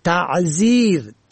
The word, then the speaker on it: تعذیر: واژه دیگر تعذیر ت عین زال یا ر به معنای عذرآورنده را تحذیر با مفاهیم ترساندن و برحذر داشتن اشتباه می گیرن و بیشتر سخنرانان نادرست تلفظ می کنن.